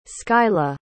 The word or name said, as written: scylla